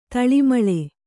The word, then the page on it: ತಾಳಮೇಳ: Kannada